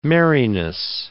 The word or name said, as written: merriness